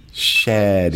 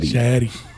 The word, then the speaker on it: Shetty: That's one of those names where you make sure you pronounce the E. Shetty. – Shetty.